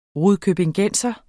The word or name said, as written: rudkøbingenser